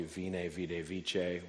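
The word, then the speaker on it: veni, vidi, vici: The most famous Latin phrase is probably vine, vide, vice, which classicists pronounce the V in Latin as a W,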